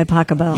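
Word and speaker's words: Pachelbel: Canon D by Pasquale. – By Pacabell. – You pronounce it Pasquale? – No.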